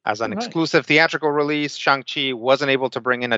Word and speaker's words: Shang-Chi: So, according to, I haven't seen it, but according to the trailers, it's pronounced like Shang-Chi, right? – Right.